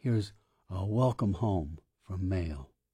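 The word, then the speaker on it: M(h)aol: That's M apostrophe bracket H close bracket AOL, pronounced Mail.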